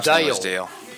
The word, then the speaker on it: Dale: Just how it's pronounced. I think that's the correct pronunciation. – Dale.